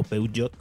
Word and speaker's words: Peugeot: Peugeot, o Peugeot, como se debe pronunciar,